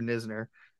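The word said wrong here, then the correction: Knizner: Andrew Kisner. – We're going to, because it's spelled like Nisner, so I always say that.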